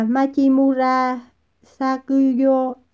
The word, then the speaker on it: Machimura Sakuyo: Đọc là Machimura Sakuyo, anh có từng nghe thấy cái tên này chưa?